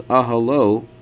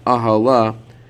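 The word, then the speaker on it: aholo (אהלה): The possessive masculine form of that word, his tent, is aholo, aleph, hey, lamed, vav. But the Torah actually spells the word that's pronounced aholo with aleph, hey, lamed, hey, which is usually pronounced as ahala, her tent.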